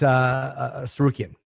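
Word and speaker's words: Tsarukyan: how do you pronounce it? No, it's Sarukian.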